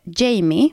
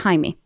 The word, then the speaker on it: Jaime: Det är Jaime Gag och vissa källor uttalar det Jamie, för mm. det är mm. så det stavas. Men om jag har fattat det rätt så är det Jaime.